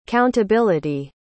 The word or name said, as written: countability